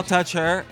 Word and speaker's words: Altucher: Reinvent Yourself by best-selling author and our guest, James Altuchus. – Well, thank you, guys. – I don't know how you pronounce this name. – You just got it right. – Altucher. – Altucher. – There you go, Altucher.